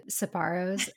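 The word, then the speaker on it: Sbarro: It all sort of melts into each other, and it's funny that you said Sbarros. – am I saying that right? Yeah, yeah, um, small.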